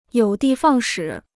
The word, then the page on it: 有的放矢: yǒu dì fàng shǐ